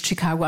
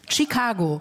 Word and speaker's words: Tschikago: Also das heißt nicht Chicago, wie das Chicago am Michigansee, sondern das Chicago, wie wir es aussprechen, mit T-S-C-H-I-K-A-G-O. Chicago.